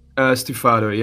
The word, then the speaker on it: stufato: Chroma and Stefano. Did I pronounce the second one correctly? – Stufato, yeah, it's an Italian word, but yeah.